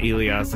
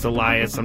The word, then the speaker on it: Elias: It's not Elias. It's Elias.